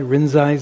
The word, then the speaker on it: Rinzai: For those of you familiar with Japanese Zen, if you heard the word Rinzai Zen, that's Rinzai is Lin Chi. That's again the pronunciation in Japanese.